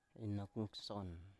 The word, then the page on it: inok ngson: Biak